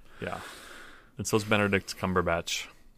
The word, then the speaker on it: Cumberbatch: You said it correctly. – I know. His name is not incorrect. One I say wrong. – Yes, it is. – Oh, that's right. Aren't you supposed to say his name wrong? I don't remember. Yes. You've told me before. – It's Bonderdick Snodgrubatch.